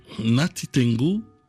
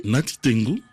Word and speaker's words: Natitingou: C'est Natitingou, la ville la plus au nord du Bénin. – Comment vous prononcez ? – Natitingou, que j'ai transformé en Natingou City.